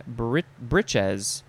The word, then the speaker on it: breeches: Next is breech, B-R-E-E-C-H, noun from before the 12th century. One is plural, and it has a different pronunciation, breeches.